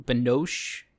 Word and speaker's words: Binoche: played by Juliette Benoche. Binoche is that is that an appropriate Juliette Binoche? Yes, p- pronunciation.